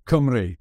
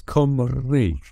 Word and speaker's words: Cymru: Cymru. Cymru. – Cymru. – Really? Yeah, there's the it's Cym, like C-Y-M, so it's Cym. And then the R-U is, you got to roll the R, so it's Cymru.